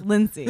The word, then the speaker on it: Linsey: And I spelled out her name, Lindsay, but I spell it L-I-N-S-E-Y. Mm-hmm. So it's just Lindsay. Lindsay. You know? It's Lindsay.